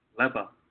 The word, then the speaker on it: Leva: How do you pronounce her name? – Leva.